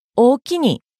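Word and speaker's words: おおきに: 標準語のありがとうを関西弁で言うと、大きに、きにアクセント。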